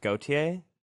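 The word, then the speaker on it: Gotye: I have truly believed this ever since the song went big, but I believe it's pronounced Gautier.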